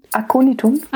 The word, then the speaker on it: Aconitum: Da steht jetzt in Klammern, dass das Aconitum heißt. Habe ich das richtig ausgesprochen? Aconitum. Ah, ja, genau.